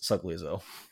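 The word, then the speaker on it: Sogliuzzo: I hope so, Mr. Sugliazo, because he was King Boomy in King Boomy previously, and I couldn't pronounce his name, so I looked it up, and I believe it's pronounced Sugliazo.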